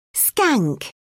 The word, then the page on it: skank: British English